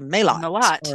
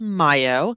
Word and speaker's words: maillot: So mayo, which is spelled M-A-I-L-L-O-T, like I would just say, oh, it's a malot. – Or yeah. – Malot. Yeah, it's pronounced mayo.